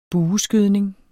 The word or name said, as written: bueskydning